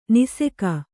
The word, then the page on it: ನಾಶಕ: nāśaka